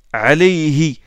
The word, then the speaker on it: Alayhi: Alayhi. On sait que « alayhi » c'est comme ça que ça se prononce.